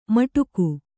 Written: ಮಟಕು

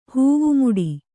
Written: ಹೂವುಮುಡಿ

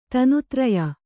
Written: ತನುತ್ರಯ

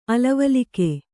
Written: ಅಲವಲಿಕೆ

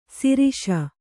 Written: ಸರೋಷ

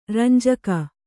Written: ರಂಜಕ

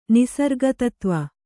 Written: ನಿಸರ್ಗತತ್ವ

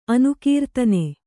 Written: ಅನುಕೀರ್ತನೆ

